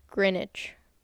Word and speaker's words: Greenwich: It is spelled Greenwich, but it's called Greenwich. – Okay, so that's... – It has no E sound and no W sound. – Greenwich.